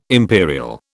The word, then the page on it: imperial: British English